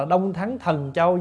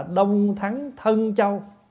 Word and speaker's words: Đông Thắng Thân Châu: Có nhiều chỗ người ta đọc là Đông Thắng Thần Châu nhưng không đúng. Đọc vậy không đúng, phải đọc là Đông Thắng Thân Châu.